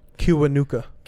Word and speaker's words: Kiwanuka: Kiwanuka. – Kiwanuka? – Yeah.